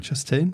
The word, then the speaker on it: Chastain: Chastain, spricht man das so aus? – Ja, ja.